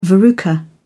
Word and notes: verruca: British English

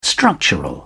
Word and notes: structural: British English

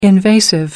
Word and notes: invasive: American English

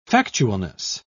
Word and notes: factualness: British English

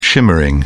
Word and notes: shimmering: British English

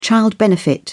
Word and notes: child benefit: British English

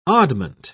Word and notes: oddment: British English

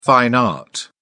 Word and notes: fine art: British English

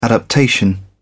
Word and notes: adaptation: British English

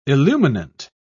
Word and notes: illuminant: British English

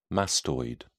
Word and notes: mastoid: British English

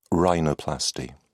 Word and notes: rhinoplasty: British English

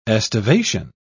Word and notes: estivation: British English

